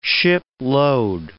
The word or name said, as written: shipload